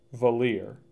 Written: Valier

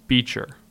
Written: Beecher

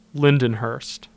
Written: Lindenhurst

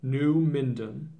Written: New Minden

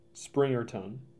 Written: Springerton

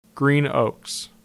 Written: Green Oaks

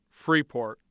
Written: Freeport

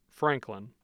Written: franklin

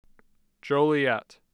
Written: Joliet

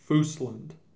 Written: Foosland

